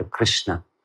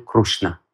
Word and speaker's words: Кришна: Строго говоря, это не Ри и не Ру, это Р. Кришна. Если совсем уже правильно фонетически повторять, то это Кришна. Но в некоторых штатах Индии этот звук «р» произносит как «ру». И они повторяют «крушна». «Харе Кришна, Харе Кришна, Кришна, Кришна, Харе Харе».